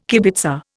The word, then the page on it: kibitzer: Indian